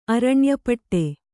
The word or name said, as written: ಅರಣ್ಯಪಟ್ಟೆ